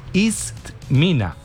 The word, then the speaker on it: Itzmina: Vea, se escribe así, se escribe como ella dijo. Itzmina, así se dice.